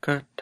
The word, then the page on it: kat: Lwaitel